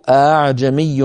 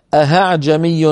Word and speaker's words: ءأعجمي: وهل صحيح أن تلفظ أهعجمي بإبدال الهمزة هاء في قراءة عاصم؟ لا يا أخي الكريم، لا في قراءة عاصم ولا في غيرها من القراءات، لا يصح ولا يجوز ويحرم إبدال الهمزة هاء إلا برواية، وليس هناك رواية.